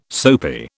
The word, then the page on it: soapy: British English